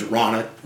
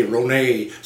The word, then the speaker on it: Ronat: That's the German pronunciation is Ronat. But yeah. My family is French, and they say Ronet sometimes.